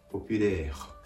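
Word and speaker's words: populaire: ポピュレーハと発音してあげるとうまく発音ができます。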